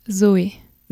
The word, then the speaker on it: Zoé: How does it sound in French? – Zoe. – Zoe. – Yeah? – That's better.